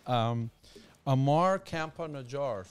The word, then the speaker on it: Ammar Campa-Najjar: Ammar Campa-Najjar.